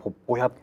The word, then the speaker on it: 鉄道員: 仁義なき戦い、えー、スターウォーズ、鉄道員かっこ高倉健さん以上です、ね、ああこれね、うん、ポッポ屋ですね。ポッポ屋ね。ポッポ屋。鉄道員っていうの？あの鉄道員って書いて、うん、ポッポ屋って読むんです。